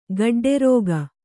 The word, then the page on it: ಗಿಡ್ಡರಾಗಿ: kannada